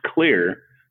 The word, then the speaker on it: Xlear: Yes, it's spelled X-L-E-A-R and pronounced Clear because it clears your nose.